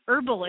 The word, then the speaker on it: herbal: But the problem is that the H in herbal is silent, and then you have health, and you did it yesterday. You want to say herbal elf.